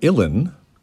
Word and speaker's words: Uilleann: The name Uilleann, which sounds like Uilleann, as the Beastie Boys would have pronounced it, comes from the Irish word for elbow, because both of those, belonging to the piper, are deployed to produce sound.